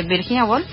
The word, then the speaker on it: Virginia Woolf: Eh, empecé a leer un cuarto propio de Virginia Woolf. ¿De Virginia Woolf? ¿Lo dije bien? Sí.